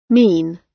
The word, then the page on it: mean: British English